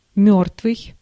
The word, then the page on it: мёртвый: [ˈmʲo̞rtvɨ̞j]